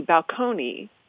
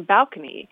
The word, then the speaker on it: balcony: Because the word had been borrowed in from Italian, and in Italian, the stress is on the second syllable, so it would be balcony. But in English, we often like to have the stress on the first syllable, so it became balcony.